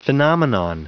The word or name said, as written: phenomenon